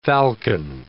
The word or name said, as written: falcon